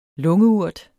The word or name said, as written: lungeurt